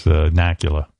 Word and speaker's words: Nakula: That's Nakula. – Rhymes with Dracula.